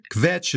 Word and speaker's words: kvetch: It's kvetch is one word. So the tell that you're not one of the tribe is that you turned it into two syllables.